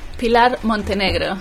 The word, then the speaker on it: Pilar Montenegro: Pilar Montenegro.